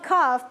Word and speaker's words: cough: คำนี้อ่านว่า cough แปลว่าไอนั่นเองเนาะด็อกเตอร์บอกว่า take some medicine and drink warm water.